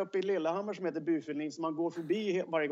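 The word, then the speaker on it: Byfuglien: Skönt att du kör det norska uttalet där också på Bufulin då, inte Bafflin utan eh, ja, det, det, Nej, det är väl lite norskt?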